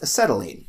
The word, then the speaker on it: acetylene: If you mix calcium carbide with water, it produces a highly flammable gas known as acetylene.